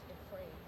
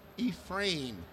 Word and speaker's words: Ephraim: His name is pronounced Ephraim. – Okay. – So. Yes. – Okay. All right. What is the pronunciation? – Ephraim Bridger and Mira Bridger.